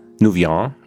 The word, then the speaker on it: Nouvian: Dort gibt es eine Arbeitsgruppe in Konstanz Neurobiologie um Morgane Nouvian. Nouvian, ja, französisch spricht man es aus und sie arbeitet mit ihrer Gruppe hauptsächlich im Abwehrverhalten von Honigbienen.